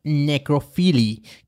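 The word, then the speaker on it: nécrophilie: El término de necrofilia fue acuñado en francés como necrophilie,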